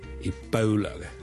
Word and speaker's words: Ebola: 最近如果大家有睇国际新闻咧，都应该会留意到 Ebola 呢个伊波拉病毒咧，喺某啲地方肆虐咁样。 Ebola 伊波拉病毒咧，我哋虽然广东话我哋译做伊。波拉波啦，我哋系 O 一个发音。但如果系英文咧，我哋通常咧系读 Ebola 嘅 Ebola，煲煲，好似弓箭嘅弓嗰个煲。